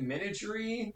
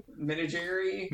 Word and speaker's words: menagerie: So she goes into the magical, how do you pronounce it? – Menagerie. – Menagerie. It's almost like menage a trois.